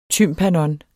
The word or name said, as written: TYMPANON